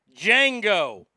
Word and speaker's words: Django: The one and only Django. The D is silent.